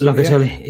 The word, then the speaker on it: Locatelli: Um, Ross, this week's rumor says, uh, well, the latest one is Locatelli. Is that how you pronounce his name? – yeah, because, Locatelli. Yeah, yeah you okay with that? Yeah, perfect.